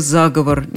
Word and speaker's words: заговор: Там есть только заговор, никаких вариантов, никаких двух значений. Заговор и все.